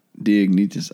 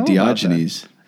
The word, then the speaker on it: Diognetus: In this epistle to Diognetus. – Diognetus. – Is that how you pronounce it? – Yeah. – Diognetus. I think I've been saying Diogenes.